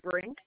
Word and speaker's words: Brink: G-E-R-R-I. – G-E-R-R-I. Okay. And your last name, spell that again. – Brink, B-R-I-N-K.